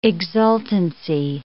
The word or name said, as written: exultancy